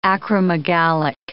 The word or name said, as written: acromegalic